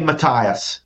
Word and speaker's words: Matthias: When the Syrians reached Modian, a town located about 12 miles northeast of Jerusalem, they demanded that the local leader, Matthias, how do you say his name? Is it Matthias? Matthiasis? Oh, he's muted. – You would probably say Matthias.